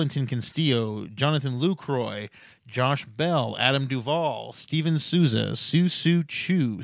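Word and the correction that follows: Shin-Soo Choo: Shin-Soo Chu, and say it right because he won me a title,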